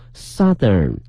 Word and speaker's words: southern: 更重要一点大家一定要记住了，南方这个词呢它是一个大口型的这个 South out 这个音 south，但是变成了南方的呢，它的发音发生了变化，变成了 southern southern。